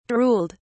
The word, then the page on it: drooled: British English